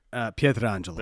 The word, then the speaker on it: Pietrangelo: I know I said his name wrong, sorry. – Pietrangelo. – There we go.